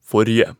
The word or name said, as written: forrige